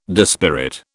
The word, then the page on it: dispirit: British English